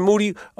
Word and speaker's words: Moody: You got anything specific you want to ask Mr. Moody, whose last name rhymes with Duty? – Well, I do love the beats, Moody.